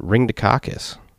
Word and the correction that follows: Ringdocus: Ringdocus, sorry. I'm adding a syllable in there.